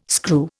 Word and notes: screw: Indian

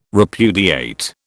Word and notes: repudiate: British English

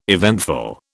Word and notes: eventful: British English

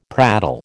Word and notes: prattle: American English

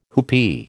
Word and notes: whoopee: American English

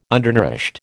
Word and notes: undernourished: American English

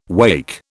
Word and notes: wacke: British English

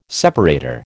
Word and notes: separator: American English

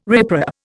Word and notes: riprap: Indian

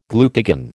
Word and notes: glucagon: American English

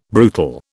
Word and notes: brutal: British English

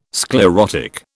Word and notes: sclerotic: British English

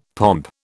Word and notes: pomp: British English

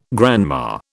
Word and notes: grandma: British English

